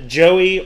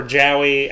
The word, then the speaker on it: Jowy: Joey or Jowey. I don't know how to pronounce it. – I said J-O-W-Y.